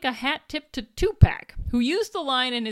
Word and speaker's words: Tupac: Tupac.